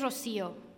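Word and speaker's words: Rocío: "My name is Rocio, with an accent on the I, meant to clue you in on how to pronounce my name right. My friends call me Chio, for short. No, my name's not Rocco. It's not Rosario.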